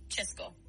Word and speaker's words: Česko: Si quieres referirte al país de forma corta, como suelen hacerlo hoy en día, dicen Česko, que se pronuncia Česko.